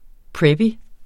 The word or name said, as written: preppy